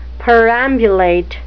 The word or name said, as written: perambulate